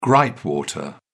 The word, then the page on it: gripe water: British English